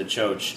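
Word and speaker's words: txotx: Well, I've already kind of touched on your cider pilgrimages, but I know there's something, I'm going to mispronounce it. Choach? – Yeah, no, that's right. Choach is right.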